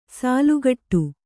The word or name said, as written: ಸೆಲೆಕೊಡು